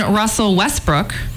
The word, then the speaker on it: Russell Westbrook: Then Russell Westbrook was leaving.